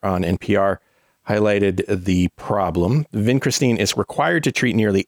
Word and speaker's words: Vincristine: This time of a drug called Vincristine.